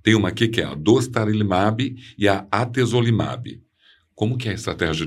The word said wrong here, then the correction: atezolizumab: Eu falei certo isso? Sim. O atezolizumab, né?